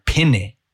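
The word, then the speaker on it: Pene: It's correct pronunciation is Aaron Penne. So the pe and the ne sound exactly the same. They have the same vowel sounds, rather than the other interpretation where people say pe and then they say nay, and it's like that doesn't make sense.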